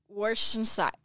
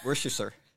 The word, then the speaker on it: Worcestershire: Worcestershire. – Worcestershire.